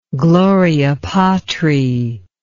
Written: Gloria Patri

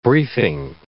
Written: briefing

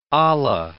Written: olla

